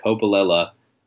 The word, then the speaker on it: Coppolella: But someone asked him how to pronounce his last name, and he did say it was pronounced Coppolella,